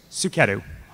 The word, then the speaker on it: Suketu: Suketu. – Spell that for me. – S-U-K-E-T-U.